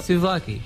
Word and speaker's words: Souvlaki: We're playing something from the 30th anniversary of their album. It's Souvlaki. I'm attributing that mispronunciation to my parents who constantly mispronounced it as I was growing up. Souvlaki.